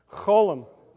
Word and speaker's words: cholam: You want to try that one? Cholam. Cholam, yeah.